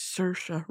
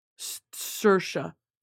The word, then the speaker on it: Saoirse: I can pronounce Saoirse right, okay? And look at how it's spelled. Saoirse. I know what I'm talking about, okay?